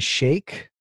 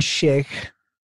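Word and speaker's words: Schaech: Yeah, and on Moody frontman Jonathan, I never learned how to say his name. Jonathan Shake. – Frontman Jonathan Shake, who looks downright frightening.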